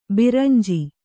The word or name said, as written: ಬೆರಂಜು